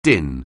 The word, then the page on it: din: anglais